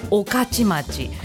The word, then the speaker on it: 御徒町: きな難読地名は御徒町という都市アット中野区民さんからのリクエスト、スーパーベルズでモーターマン秋葉原南浦和館お送りいたしました。御徒町、お礼とかの恩ね、恩中とかの恩に徒競走の都に町で御徒町、まあ昔からこれは御徒町って思って読んでいたから御徒町って読めるけど、確かに初めて見た方はこれなんて読むのって思うよね、御徒町ね。